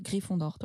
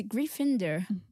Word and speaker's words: Gryffindor: Je vois pas un Anglais dire Gryffondor, toi. Ou c'est pas possible. – Mais, c'est Gryffindor.